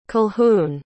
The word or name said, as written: colquhoun